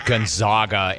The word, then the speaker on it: Gonzaga: The bird wants to correct your pronunciation of Gonzaga and remind you that it's the Zags, Gonzaga.